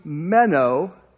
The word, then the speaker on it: meno: Okay, so M-E-N-O, okay, like a long O. So it's pronounced meno.